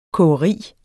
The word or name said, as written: kogeri